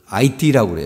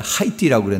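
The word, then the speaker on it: 아이티: 화요일날 12일날 오후에 그 IT라고 그래요. 원래는 우리는 옛날에 하이티라고 그랬는데 이게 그게 불란서 영이기 때문에 그 H자를 발음을 안 하고 IT라고 그래서 그곳에 7.0 규모의 강진이 일어났습니다.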